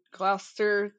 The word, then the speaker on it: Gloucester: And I say Gloucester, I mean, living in the North now, I found out that anything that ends in the C-E-S-T-R, like Worcester and Gloucester, that's how that's pronounced.